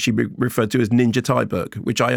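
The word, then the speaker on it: Ninja Thyberg: I interviewed her and her name in Sweden would be pronounced Ninja Tibri. But I said, how would you like me to say it? – Do that again. – Ninja Tibri. – I like that. – And she said, no, call me Ninja Tyberg. And I said, oh, really? Why? She said, well, because I prefer it to be said like that.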